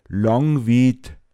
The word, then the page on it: Foachetz: Pinzgauer Mundart